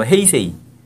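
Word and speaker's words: 헤이세이 (平成): 그래서 1989년까지 쇼화 시대였고 1989년부터 이제 평성 일본 말로 헤이세이. – 그래서 오. 지금 뭐 어. – 올해가 헤이세이 27년이에요.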